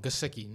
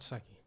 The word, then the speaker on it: Gesicki: You're still a little wrong. Gesicki, not Secchi.